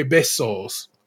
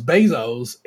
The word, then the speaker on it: besos: Abdul, who was one of I think three people who stepped outside the concha bun, as it were, made coconut and raspberry besos, which they pronounce bezos, and it made me just say, y'all really don't be trying.